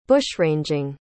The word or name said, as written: bushranging